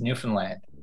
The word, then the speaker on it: Newfoundland: First of all, I'll do my friends from The Rock a favor, and I'll tell you that it's pronounced Newfoundland.